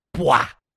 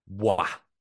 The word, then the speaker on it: Dubois: I was gonna say another thing I find really funny on Hockey Reference is they have the pronunciation, and it says in capital letters P E E, and then A-I-H-R, and then L-E-W-K, and then it's D-O-O, yeah. and then in capitals, B-W-A, Bois.